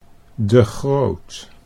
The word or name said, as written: De Groot